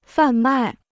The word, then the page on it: 贩卖: fàn mài